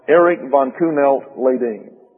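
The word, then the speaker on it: Erik von Kuehnelt-Leddihn: And it turns out his name is pronounced Erich von Kuhnelt-Leydin.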